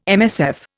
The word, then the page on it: MSF: Indian